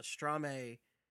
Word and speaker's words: Strome: I believe the pronunciation on those are Strame and Spoener.